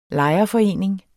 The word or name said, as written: lejerforening